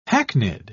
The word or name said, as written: hackneyed